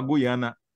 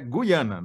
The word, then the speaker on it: Guiana: O Thales me mandou matéria do próprio UOL, baseada num depoimento do professor Pasquale, dizendo que a expressão, o jeito certo de falar Guiana em português brasileiro é Guiana, não é Guiana.